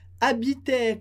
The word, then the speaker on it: habitaient: Elas habitaient com ENT no final, que a gente não pronuncia. E a liaison, elles habitaient.